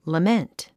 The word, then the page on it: lament: ləmént; ラメント